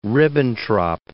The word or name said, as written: Ribbentrop